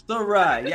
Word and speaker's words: Sayrie: Sayrie.